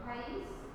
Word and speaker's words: país: País. – País en la I.